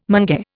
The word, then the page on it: mange: Indian